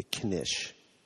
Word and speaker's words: knish: It's such a cute name, the knish. – Yes, well, thank you. I think so, too. It's important to note it's one syllable and that the K is pronounced.